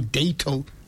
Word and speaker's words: Dato: Is it Dato Seiko or Dato Seiko? How do you pronounce it? – It's Dato. – Dato.